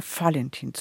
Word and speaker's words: Valentin: Also eigentlich kann man es halten, wie man will, aber hier ist, ist es üblicher, Valentin zu sagen mit F ausgesprochen.